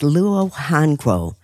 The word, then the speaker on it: luohanguo: The next natural sweetener is luohanguo. That's spelled L-U-O, new word H-A-N-G-U-O.